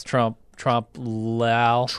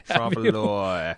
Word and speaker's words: trompe-l'oeil: And today we're talking about Season 1, Episode 7, called some French word I can't pronounce. Trompe-l'oeil. That sounds right. You nailed it. Got it in one. – I think I did. I've been practicing all morning. Trompe-l'oeil.